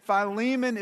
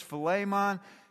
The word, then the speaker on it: Philemon: Philemon is the Greek pronunciation. The English pronunciation is Philemon.